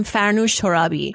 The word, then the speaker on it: Farnoosh Torabi: And for those of you wondering, I'm Farnoosh Torabi.